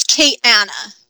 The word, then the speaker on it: Kayana: So it's Kayana.